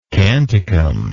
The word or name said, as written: canticum